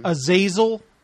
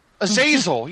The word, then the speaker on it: Azazel: Azazel. – Azazel.